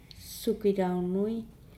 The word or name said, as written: sugi launui